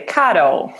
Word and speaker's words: Caro: Nearly. Caro. – Caro. – It's like Carol. Yeah.